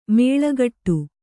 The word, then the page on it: ಮುಳ್ಳುಗಿಡ: muḷḷu giḍa